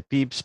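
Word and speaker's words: Pepys: Pues entonces, a ver, eh, Hombre, un dietario, el padre, yo creo, de todos los dietaristas es, em, se dice Pips, pero se escribe Pepis,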